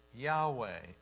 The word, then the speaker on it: Yahweh: And they came up with a pronunciation. And the pronunciation for I am is Yahweh in the Hebrew.